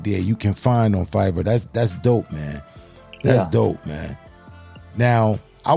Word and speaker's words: Fiverr: How you pronounce Fiverr, right? – Fiverr.